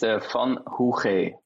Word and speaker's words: van Hoogge: Wie spricht man deinen Nachnamen aus? – Meine Nachname ist van Hoogge.